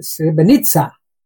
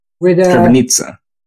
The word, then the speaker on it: Srebrenica: So today we're going to be talking about your experiences with the Srebrenica. How did you pronounce it again? – Well, the Serbs said Srebrenica. – Srebrenica. – Yes. Quite right. It resembles a bit the Italian way of pronouncing that word.